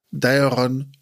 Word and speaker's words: Daeron: Denn Maglor war ein großer unter den Sängern von einst, der gleich nach Diadorn, wie spricht man den nochmal aus? – Daeron und Doriath genannt wird.